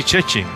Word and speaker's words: Szczecin: Se pronuncia sencillito así, Szczecin, pero cuando usted la ve escrita, son un montón de consonantes, una pegada detrás de la otra, que usted no se puede imaginar que Szczecin es una ciudad fronteriza con Alemania.